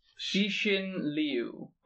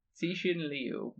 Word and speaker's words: Cixin Liu: It is Ball Lightning by, and I looked up the pronunciation, it's Cixin Liu. – Okay. – Cixin Liu. Spelled C-I-X-I-N-L-I-U.